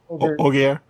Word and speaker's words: Holger: And like when he, one of the explanations we get as to why, Holger, well, how do we say his name? Holger? – Holger.